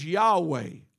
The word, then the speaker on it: Yahweh: The closest we can come in pronouncing this name, y'all got to catch this, is Yahweh or Yah.